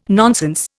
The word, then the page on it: nonsense: Indian